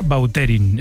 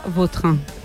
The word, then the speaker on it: Vautrin: Es que a mí me suena mejor Bauterín. Es como... – Da más fuerza, ¿no? – Más fuerza, más pronunciación. – Bauterín. Elegante. – La original francesa, Vautrin.